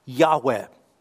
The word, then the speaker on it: Yahweh: The sacred four letters, YHWH, is how it would be transliterated into English. The most logical pronunciation of that would be Yahweh.